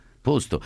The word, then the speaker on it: posto: Prego te e i tuoi ospiti di non pronunciare mai le parole dopo, posto, risposta, 14, con la O chiusa. È sbagliato e di cattivo gusto. I toscani pronunciano con la O chiusa. I toscani pronunciano in maniera scorretta. La lingua italiana è ufonia. Aiutatemi. Perché? E si dice dopo, risposta, posto.